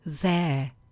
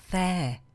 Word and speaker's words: there: Co nám tam tak trochu dělá neplechu je, jak už to v angličtině ostatně bývá, výslovnost, která u toho prvního slova zní where, u druhého here a u toho posledního there. Where, here, there.